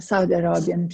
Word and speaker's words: Saudiarabien: Saud Saudiarabien, eller hur uttalar man det? Eh, Saudiarabien precis.